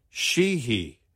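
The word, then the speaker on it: Sheehy: Or the new GOP candidate in Montana, she, he.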